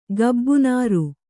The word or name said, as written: ಗಬ್ಬುನಾರು